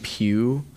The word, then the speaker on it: Pugh: I have last name Pugh, spelled on my grandma.